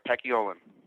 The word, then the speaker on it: Paciolan: It's Paciolan? – Yeah, you got that right, Paciolan.